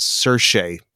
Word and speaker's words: Saoirse: And I did find out from my Irish friends that the pronunciation of her name is Saoirse. Don't question it. Just don't even look at the letters when trying to pronounce.